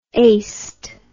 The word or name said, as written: aced